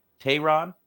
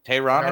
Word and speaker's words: Teheran: Let's get on to somebody who I do struggle pronouncing his name, but I got it today, and that's Julio Teheran. Finally got it right after Teheran. I said Aaron over here.